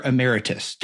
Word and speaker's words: emeritus: You were a professor emeritus. I said that correctly, by the way? – Yeah. Yes. Very nice.